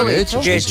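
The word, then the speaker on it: Getxo: En Guecho, que nunca sé cómo se pronuncia. – ¿Guecho? – Guecho. Yo creo que lo pronunció bien. – Muy bien.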